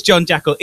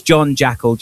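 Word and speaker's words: Jon Jackal: It is John Jackal.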